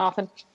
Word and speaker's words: often: Or often, as we say. Anyways, yes. – We just dropped the tea. – Yeah, just drop often. Often. – Mm-hmm. Yep, very often. – It rhymes with coffin.